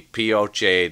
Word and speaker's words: Pioche: P-I-O-C-H-E. – Pioche. Could be the Pioche Desert, too. I'm going to say desert. – That's a desert. – Yeah! – It probably is pronounced Pioche. – That's right, it is.